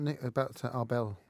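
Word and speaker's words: Abel: Tell us about Arbel. Actually. – Yeah, well, of course, they just chose really a mathematician who kind of rhymes with Nobel, and people think it is the Nobel Prize.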